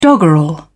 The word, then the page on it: doggerel: British English